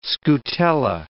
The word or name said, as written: scutella